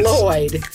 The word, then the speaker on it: Lloyd: Put your tongue where you're going to about to say sugar and then instead blow out on the sides. – Say Lloyd.